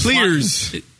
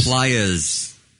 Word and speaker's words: Plies: It's spelled P-L-I-E-S. – The community. – The old man. – I don't know. I don't go to that community. – Everybody knows what you're talking about when you're talking about the community. – Right. – In quotes. Table quotes. – There you go. – The community. – Right. – Thanks. What was his name? – Plies. – Yeah. P-L-I-E-S. – Yeah. – Plies. – Yeah. It's spelled like flies.